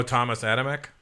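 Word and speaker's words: Tomasz Adamek: Vitaly is fighting, how do you say his name? Tomacek? – Oh, Thomas Adamek? – Adamek, yeah.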